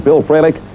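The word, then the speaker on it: Fralic: In addition, from the Atlanta Falcons, Bill Frelick, what? And a host of others. – You hear that? He said it right.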